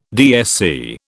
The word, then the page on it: DSC: British English